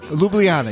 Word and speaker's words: Ljubljana: Ljubljana. – Ljubljana. Yeah, I wasn't even going to try and pronounce the city. Ljubljana, Slovenia.